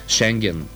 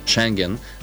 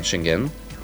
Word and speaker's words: Шенген: Румъния активизира контрола по границите си с тези показни акции, както и България показно се опитва да докаже, че сме готови за Шенген, както е правилното ударение, уважаеми слушатели. Благодарение на министър Младенов знаем точното ударение на Шенген, а не Шенген.